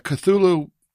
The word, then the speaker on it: Cthulhu: So the Cthulhu mythos, I hope I'm saying that right.